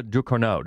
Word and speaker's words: Ducournau: This is co-writer and director Julia Ducournau.